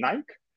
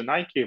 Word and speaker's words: Nike: Кстати, есть два произношения, Nike и Nike зависит от того, а, в Европе это чуть больше Nike, в Америке это Nike.